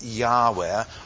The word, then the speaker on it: Yahweh: And he's revealed under the name of what we call the Tetragrammaton, YHWH, and that's often pronounced Yahweh, or translated in our Bibles, Jehovah.